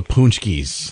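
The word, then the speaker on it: Pączki: How was the Punchkies this year? – The Punchkie business. About Punchkies, son. You said it right.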